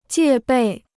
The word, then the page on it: 戒备: jiè bèi